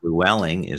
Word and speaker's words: Flewelling: And Flewelling is spelled Flewelling.